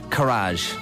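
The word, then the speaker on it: karaage: And it was called Chicken Courage, right? – Okay. It rhymes with Nigel Farage.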